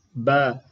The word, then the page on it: b: Sanskrit